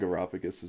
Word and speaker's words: Areopagus: In front of the Areopagus, or however that word's pronounced. – Yeah, yeah. I think Areopagus is right.